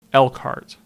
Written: Elkhart

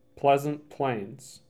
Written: Pleasant Plains

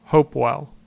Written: Hopewell

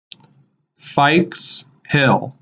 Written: Fikes Hill

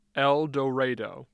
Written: Eldorado

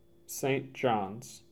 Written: St. Johns

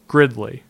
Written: Gridley

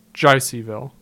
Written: Jeiseyville

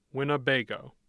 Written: Winniebago

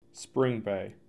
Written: Spring Bay

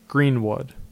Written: Greenwood

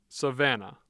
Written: Savanna

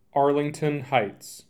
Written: Arlington Heights

